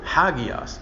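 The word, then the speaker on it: hagios (ἅγιος): The straight line is an accent mark that just tells you that the emphasis should be on the first syllable, hagios. But that little backward comma up there is called the rough breathing mark. It means you're supposed to breathe it like an H.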